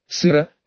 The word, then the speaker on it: сыро: Он вышел из машины в галошах, понюхал воздух, сыро, сказал он с ударением на «о», влез обратно в машину и уехал.